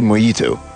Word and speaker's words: mojito: It was later and I found out that it is actually mojito. It's pronounced mojito. It's a J, but it's pronounced mojito.